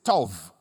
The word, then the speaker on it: tov: Now, in Hebrew, the word good is tov. It's spelled with B, but it's pronounced as V, because there are two different ways of pronouncing that letter.